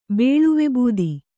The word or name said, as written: ಬೇಳುವೆಬೂದಿ